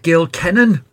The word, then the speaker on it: Gil Kenan: And that was my conversation with Gil Kenan,